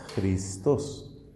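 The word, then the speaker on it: Christos (Χριστός): Cristo en griego pues se escribe parecido, pero la pronunciación es distinta. Christos. Tiene en la primera letra, tiene una J. La letra J que parece como una X alargada.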